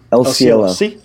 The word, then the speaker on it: El Cielo: And the first thing out of my mouth was El Cielo. – Cool. El Cielo. – I'm not pronouncing right. – El Cielo.